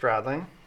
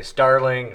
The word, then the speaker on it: Stradling: Some people say Starling, Sterling, Straddling, you know, it just goes on and on and on.